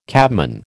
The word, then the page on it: cabman: American English